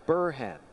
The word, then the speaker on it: Burhan: And the important thing is the emphasis on that first syllable, Burhan.